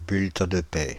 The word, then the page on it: bulletin de paie: France (Île-de-France); /byl.tɛ̃ də pɛ(j)/; /byl.tɛ̃ də pɛ/